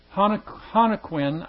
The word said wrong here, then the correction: Joaquin: I believe, is how you would pronounce it. It's Joaquin.